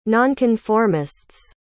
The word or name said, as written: nonconformists